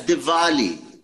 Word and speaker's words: Diwali: No, it's Diwali. Say ver for verbiage. – Because it's pronounced Diwali. – Vermont. – It's Diwali. – Spelled with a W. – V for Vermont.